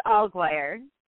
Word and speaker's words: Alguire: Did I say your last name right? – Pretty close. It's Alguire.